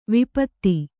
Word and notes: ವಿಪತ್ತಿ: vipatti

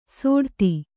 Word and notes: ಸೋಡ್ತಿ: sōḍti

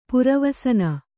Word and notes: ಪುರವಸನ: pura vasana

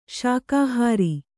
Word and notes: ಶಾಕಾಹಾರ: śakāhāra

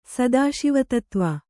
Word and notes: ಸದಾಶಿವತತ್ವ: Kannada

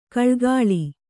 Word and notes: ಘಳಘಳ: ghaḷaghaḷa

